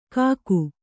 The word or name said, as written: ಕಾಕು